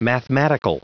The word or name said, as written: mathematical